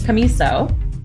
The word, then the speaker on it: Commisso: I have Jennifer Commisso.